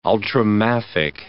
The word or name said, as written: ultramafic